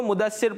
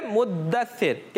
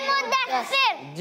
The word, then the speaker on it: मुद्दसिर: इसकी प्रोनाउंसिएशन में थोड़ी गलती हो जाती है, लोग इसको मुद्दसिर पढ़ लेते हैं, मगर इसकी जो दाल है मुद्दसिर, दाल पर क्या है? – तजदीद है, इसलिए इसको पढ़ेंगे सूरह मुद्दसिर। क्या पढ़ेंगे? – सूरह मुद्दसिर। – जी, तजदीद के साथ पढ़ेंगे, और तजदीद के बगैर पढ़ेंगे तो ये प्रोनाउंसिएशन गलत हो जाएगी।